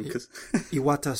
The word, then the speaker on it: Iwata: We actually took a break here a moment and looked up how to pronounce it. – I've only over, we've, you know. – Read it. – You read it. And no disrespect intended, but. – Iwata. – Iwata Satoru.